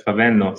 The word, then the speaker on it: Favendo: We have here a special guest, visiting us, CEO of Favendo, right? – Yes, Favendo. Sounds Italian. Pronounce it perfectly.